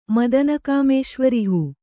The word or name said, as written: ಮದನಕಾಮೇಶ್ವರಿಹೂ